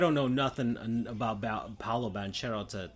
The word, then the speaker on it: Paolo Banchero: Paolo Banchero. There we go. That's how you say it. I keep thinking of him like as if he was Brazilian, but no, he's Italian American.